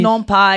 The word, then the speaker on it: non pas: So how do you say the S's? – Ne pas. – Ne pas. – Ne. – So you don't pronounce the second N? – Ne pas. Wait, it's N-O-N-P-A-S. – Oh, non, non pas. Yeah. – Non pas. – N-O-N.